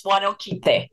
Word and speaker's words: Huanoquite: Huanoquite, if I'm saying that correctly. – That's correct. It's Huanoquite.